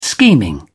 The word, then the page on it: scheming: British English